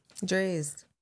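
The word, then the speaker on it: Drai's: I went to Dreya's nightclub. – Dre's. – Yeah. I say it wrong. Say that again. – Dre's.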